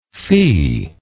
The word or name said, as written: fi